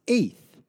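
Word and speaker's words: eighth (אֵת): And then the word "with" is essential because this is the translation of the Hebrew preposition pronounced "eighth," which, if you are taking notes, is spelled E-T.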